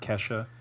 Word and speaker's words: Kesha: He was former tech for Nine Inch Nails and Keisha. – There you go. – A tech. It's pronounced Kesha.